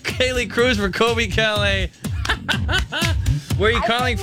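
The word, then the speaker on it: Colbie Caillat: Callie Kalei, I think how you say your name. – Not quite. – Colby Kalei. – That's right.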